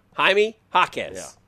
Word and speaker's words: Jaime Jaquez: Jaime Jaquez. – Yeah. – It's a soft J.